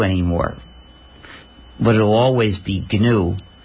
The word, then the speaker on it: GNU: So the G is pronounced. – Yes, of course. – You've heard it here. – If you called it the new operating system, you'd get people very confused.